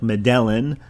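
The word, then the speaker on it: Medellín: It's spelled M-E-D-E-L-L-I-N. I guess if you just spelled or pronounced it phonetically, it would sound like Medellin, but with the accent, it's Medellin.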